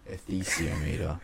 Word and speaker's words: esthesiometer: Ethesiometer.